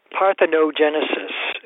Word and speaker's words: parthenogenesis: Just to learn how to pronounce it, took me a while. Parthenogenesis. It comes from Parthenogenesis. – Parthenon. – Okay. That Parthenon, yes. – Right, like the Parthenon in Greek.